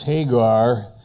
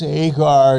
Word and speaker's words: Hagar: For this Hagar is Mount Sinai. There's an H there. It's in our King James Agar, but there's a silent H there. From this Hagar is Mount Sinai in Arabia, and answereth to Jerusalem, which now is and is in bondage with her children.